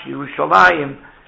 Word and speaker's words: Yerushalayim: So that's why it's called Yossi ben Yochanan Ish Yerushalayim, even though it's pronounced Yerushalayim, but it's written as if it would be Yerushalayim.